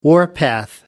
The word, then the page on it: warpath: American English